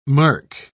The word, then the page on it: mirk: British English